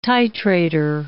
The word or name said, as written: titrator